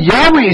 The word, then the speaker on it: Yahweh: And in the Hebrew it is pronounced something like this. Yahweh. And Hallelujah, when you say, we sang Hallelujah, right, this morning? Hallelujah. That's, again, Yahweh is coming there.